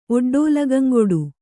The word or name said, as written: ಒಡ್ಡೋಲಗಂಗೊಡು